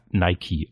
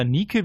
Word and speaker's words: Nike: Ja, jedenfalls wird er dann auch erkannt von dem Chef der Abteilung 3, Nike Quinto oder wird das Nike ausgesprochen wie die Schuhe? – oder, oder Nike wie die, wie die griechische Göttin.